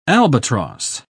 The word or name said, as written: albatross